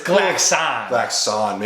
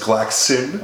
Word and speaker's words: Glaxon: What is Glaxon? It's Glaxon. – Make sure. Yeah. Unless you're Sam Strange, who says that the traditional English pronunciation, because he's from Europe, is Glaxin.